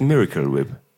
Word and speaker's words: Miracle Whip: Es heißt Miracle Whip. Das ist tatsächlich die eigentliche Aussprache. – Aber es wird doch im Deutschen, für den deutschen Markt wird es doch tatsächlich Miracle geschrieben. – Ja, ja, auch ausgesprochen in der Werbung.